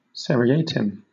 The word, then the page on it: seriatim: British English